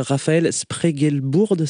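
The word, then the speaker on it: Rafael Spregelburd: Alors est-ce que je prononce bien déjà, est-ce que c'est bien Raphaël Spregelburd, c'est ça ? – Oui, tout à fait.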